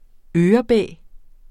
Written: ørebæ

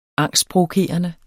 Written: angstprovokerende

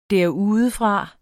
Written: derudefra

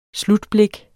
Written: slutblik

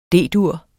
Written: D-dur

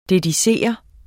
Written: dedikere

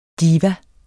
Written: diva

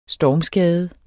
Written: stormskade

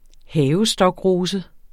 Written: havestokrose

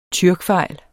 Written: tyrkfejl

